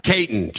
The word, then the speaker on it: Caton: Did I pronounce it right? Caton? How do I pronounce it? – Nah, it's Caton. Just Caton. – Caton. Simple. Nice. All right. Simple. I was too fancy. I gave you the French pronunciation.